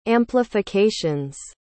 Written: amplifications

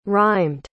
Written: rhymed

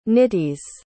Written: nitis

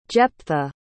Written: jeptha

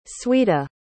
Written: sweta

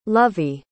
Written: lovie

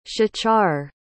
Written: shachar

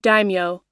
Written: daimyo

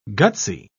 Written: gutsy

